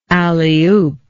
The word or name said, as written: alleyoop